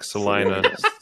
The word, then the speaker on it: Salina: It's not like Selena Gomez. It's like Salina, saliva. So you did Salina. Say it correctly.